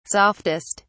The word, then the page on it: softest: British English